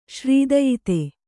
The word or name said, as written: ಶ್ರೀದಯಿತೆ